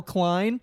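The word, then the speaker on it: Kline: I even say that for people because there's multiple ways to spell Klein, and I spelled it out, and they still misspelled it.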